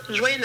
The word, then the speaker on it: joyeux: So for the first word, joyeux, there is two syllables. The first one is joie, and the second one is yeux.